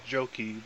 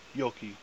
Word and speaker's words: Joki: Well, you see, everybody butchers my last name because it's a Finnish name, and I've heard a thousand different pronunciations, and the American version of it is Joki, but in Finland, you would pronounce it Joki.